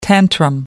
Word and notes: tantrum: American English

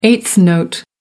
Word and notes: eighth note: American English

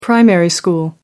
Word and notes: primary school: British English

